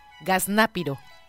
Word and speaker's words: gaznápiro: Y es gaznápiro. – Gaznápiro. – Gaznate, no, gaznápiro. – ¿Gazná? – Gaznápiro. – Gaznápiro. – Ajá, okay. Con Z. Gaznápiro y acento en la A. Gaznápiro.